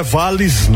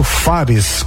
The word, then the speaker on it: Fares: Ó, menina, não é Vales não, viu? É porque também o meu, esse sobrenome é ruim de pronunciar mesmo, aí a pessoa entende de todas as formas. É Maurício Fares com F de Francisco, ok?